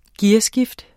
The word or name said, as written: gearskift